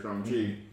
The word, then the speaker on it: Shang-Chi: Shang-Chi.